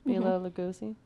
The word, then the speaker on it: Bela Lugosi: Bela Lugosi. Do I say it right? – Bela. – Bela Lugosi.